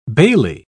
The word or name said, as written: bailie